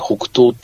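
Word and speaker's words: 北投: で、そんな中で一番台湾で大きな温泉っていうのはどこかっていうと、台北市の台北市のこのちょっと北側にですね、北東っていうところがあります。はい、北に投げると書いて北東って言うんですけど、ここが一番大きくてですね、はい、かつ泉質もいいんですよね。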